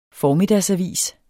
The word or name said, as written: formiddagsavis